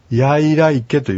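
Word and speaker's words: イヤイライケレ: まあ、これは私も実際きちっと聞いてないので、あの、なかなか難しいんですけども、まあ、いを強くさえ発音すれば、あの、間違いじゃないので、やいらいけというふうに、あの、発音していただくと、あの、感謝するとかお礼を言うという単語になります。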